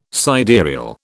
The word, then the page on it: sidereal: British English